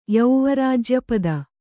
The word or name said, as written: ಯೌವರಾಜ್ಯಪದ